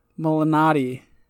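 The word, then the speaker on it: Malnati: Yeah, it was founded by Lenat by Rudy Lenati, or the son of Rudy. M- uh, I can't even say it. Malinati. But so I learned today that I've been saying it wrong this whole time.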